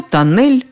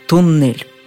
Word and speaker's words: тоннель: Самые авторитетные в области произношения словарь ударения Агеенко и Зарвы и орфоэпический словарь под редакцией Аванесова в один голос подтверждают, что тоннель, что туннель – все равно.